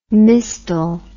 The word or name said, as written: mistal